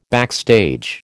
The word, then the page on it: backstage: American English